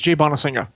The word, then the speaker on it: Jay Bonansinga: How do you say, is it Jay Bonansinga? – Yeah. I hope I don't slaughter this myself. Yeah, I believe it's Jay Bonansinga. That's how I heard him pronounce it.